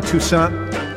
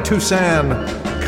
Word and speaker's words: Toussaint: Bunch of Alan Toussaint. I don't pronounce it that way. A bunch of Alan Toussaint compositions and productions in that last set.